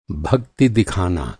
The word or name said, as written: भक्ति